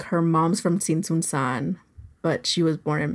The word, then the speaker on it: Tzintzuntzan: And I love this name, Tsintsunsan. – Yeah. And it rolls off the tongue. – It really does. And we should put it in our notes too, because it's spelled like Tzintzuntzan.